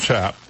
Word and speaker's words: cap: Wolvengray explained that Mayo, which is spelled M-E-Y-I-W-Y, but pronounced Mayo or Mayu, means pertaining to excrement, while Cap, pronounced Chap, C-A-P, pronounced Chap,